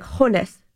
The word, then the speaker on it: Jonas: And Jonas Brodin is like Honus. Honus.